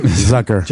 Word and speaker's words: Zucker: Zucker. – Jeff Zucker? – Jeff Zucker. – Yeah, it rhymes with fucker.